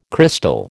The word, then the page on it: crystal: American English